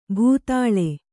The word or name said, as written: ಭೂತಾಳ